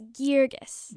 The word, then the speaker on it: Girgis: It's Girgis. – Girgis. Okay, I thought I probably did it wrong. I thought it was with the hard G.